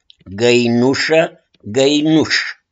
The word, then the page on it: GĂINUŞĂ: GĂINUŞ